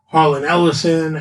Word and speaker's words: Harlan Ellison: That's written by Harlan Ellison and if I say his name right, yeah, that's right.